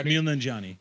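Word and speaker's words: Kumail Nanjiani: Camille Nanjiani.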